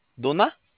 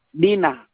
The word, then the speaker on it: DINA: ¿Duna? – Dina, Dina.